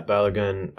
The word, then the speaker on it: Balogun: You had another one who is Balogun, yeah? – Yeah, Balogun.